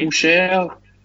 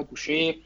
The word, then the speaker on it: Boucher: Da haben sich mit mit Len und mit ähm, Baines und ich weiß nicht wie wie spricht man den einen aus? Chris Boucher. – Boucher. – Ja, genau Boucher.